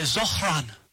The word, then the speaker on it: Zohran: I said Zoran Mamdani. You're supposed to say Zohran.